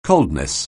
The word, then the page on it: coldness: anglais